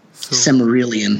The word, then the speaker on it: Silmarillion: Silmarillion.